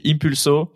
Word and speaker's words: Impulso: L'association, c'est Impulso. Je prononce bien Ouais, nickel.